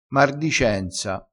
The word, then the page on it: mardicenza: dialettale